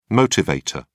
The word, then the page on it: motivator: British English